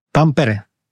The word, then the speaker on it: Tampere: Did I say that right? – Tampere. – Tampere. So close enough.